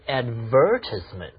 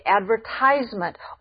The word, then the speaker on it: advertisement: Why do I hear some people say advertisement? – Ah, that's the British pronunciation. Americans say advertisement, or ad for short.